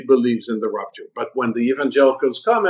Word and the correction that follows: rapture: I have to clarify this from pronunciation perspective because I'm enjoying it, but it's rapture. It's not rupture.